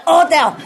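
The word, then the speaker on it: hôtel: And if it's an age, you just don't say age. You know, you don't say hotel. You cut the ha ha ha. You cut the ha away. You say hotel.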